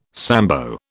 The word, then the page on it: Sambo: British English